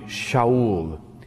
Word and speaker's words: Shaul: Well, of course, Paul's name originally was not Paul, but Saul, or in his pronunciation, probably Shaul.